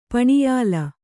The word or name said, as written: ಪಣಿಯಾಲ